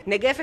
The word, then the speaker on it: Negev: Porque estáis presentando una propuesta muy especial, como irnos de viaje al desierto de Negev, que no sé si es Negev o Negev, ¿cómo se dice? – Negev está fenomenal.